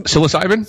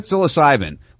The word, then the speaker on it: psilocybin: Oh, psilocybin, psilocybin, which you get.